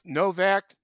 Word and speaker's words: Novak: He was Novak.